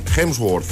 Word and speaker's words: Hemsworth: Donde vas a ver la imagen de los hermanos, a ver si lo digo bien esto, Hemsworth. – Lo has dicho bien.